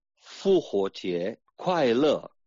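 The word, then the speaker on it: Fu Huo Jie, Kuai Le (复活节快乐): Bueno, pues la expresión de feliz Pascua, de resurrección, se dice Fu Huo Jie, Kuai Le.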